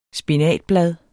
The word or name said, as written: spinatblad